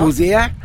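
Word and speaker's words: Hozier: Ist mir bis heute noch nicht, wie der ausgesprochen wird. Also du hast uns ja jetzt aufgeklärt. – Genau. – Hoosier. – Genau. – Oder nicht Hoosier oder sonst irgendwas.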